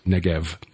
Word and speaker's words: Negev: So if you're trying to look it up, it's O-B-A-R-I-M. – Right, right. Ovarim, just like the Negev. In English, we've changed the B to a V. It normally would be spelled with a B, but it's pronounced Negev, the desert in south of Israel.